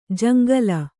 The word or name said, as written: ಜಂಗಲಿ